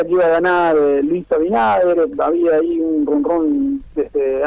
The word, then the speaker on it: Abinader: Sí, porque hubo elecciones y ganó Luis Abinader, ¿se pronuncia así? – Exactamente, Luis Abinader, yo no le veo Abinader. – Si yo lo pronuncio Abinader, como bien. Escucho que lo dicen los dominicanos.